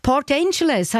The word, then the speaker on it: Port Angeles: Elisabeth Hegi von Port Angeles, habe ich es richtig gesagt? – Ja, das ist richtig.